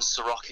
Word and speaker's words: Ceroc: I don't actually have a personal YouTube channel myself, but we have a company YouTube channel, so it's Seroc Official, and Seroc is spelled C-E-R-O-C,